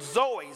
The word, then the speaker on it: zoe: It's a Greek word, zoe, Z-O-E. That's how it's spelled.